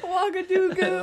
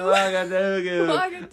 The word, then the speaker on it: Ouagadougou: Ouagadougou. – What is it? – Ouagadougou. – Ouagadougou.